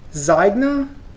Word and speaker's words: Zynga: It's by a company, Zynga, I think is the way I'd pronounce it. Z-Y-N-G-A is... – Zynge.